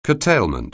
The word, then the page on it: curtailment: anglais